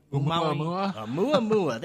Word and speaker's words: Oumuamua: In the past few years, we've had the I always mispronounce that thing. Oumuamua. There it is. The supposed alien space. – I have trouble with it too. I don't know anybody who doesn't. – Yes, Oumuamua.